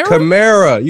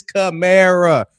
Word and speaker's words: Kamara: You say it like Camaro. Announcers fuck it up continuously. I heard Shannon Sharp. He said, they got Kamara. I said, it's Camara.